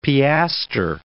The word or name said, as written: piaster